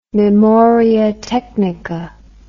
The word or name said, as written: memoria technica